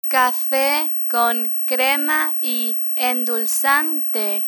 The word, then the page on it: cafe con crema y endulzante: spanish